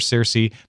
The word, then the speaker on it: Circe: The accepted pronunciations, though, are to do the C's as S's. That's why you hear Cicero. That's why you hear Circe.